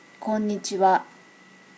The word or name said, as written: konichiwa